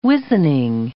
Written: wizening

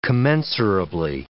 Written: commensurably

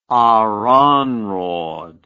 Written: Aranrhod

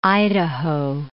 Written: Idaho

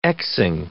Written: x'ing

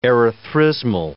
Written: erythrismal

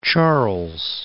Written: Charles

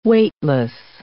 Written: weightless

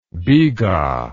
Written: biga

